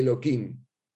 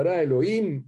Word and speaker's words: Elohim: Por eso los sefaradín siempre tenían cuidado, cuando decían nombre de Hashem lo decían tal cual, en una de de no se dice Eloquín. Se dice el pasuk entero. Bereshit Elohim esta llamada No se dice la Q.